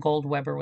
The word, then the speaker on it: Goldweber: And, and so if you Google that, I have an, it's spelled Marianne with, it's one word with an I, and then Goldweber with one B.